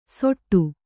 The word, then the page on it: ಸೊಟ್ಟು: soṭṭu